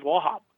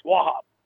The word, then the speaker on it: Wauchope: It's spelled W-A-U-C-H-O-P-E. But how it's pronounced is Wahab. Wahab's plantation, right? So I don't know how you got Wahab out of Wachapi or whatever how you spell it, but it's Wahab.